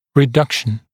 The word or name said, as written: reduction